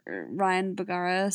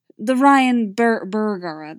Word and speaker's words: Bergara: But like, Jason has a little bit more of that metaphysical weight, so it fits really well with him being the more Ryan Bergara star, the Ryan Bergara, that's the pronunciation, sorry.